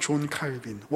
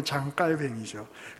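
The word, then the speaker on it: Calvin: 심지어 칼빈은, 우리가 잘 알고 있는 영어식으로는 존 칼빈, 원래 발음으로는 장 깔뱅이죠.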